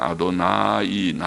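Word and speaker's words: 아도나이 (אֲדֹנָי): 이게 주라고 아도나이, 아도니 짧게 아도나이. 그러나 하나님을 향해서 주님을 할 때는 아도나이, 나자가 좀 깁니다. 발음을 할 때 아도나이 그렇게 하고 사람을 향해서도 아도니.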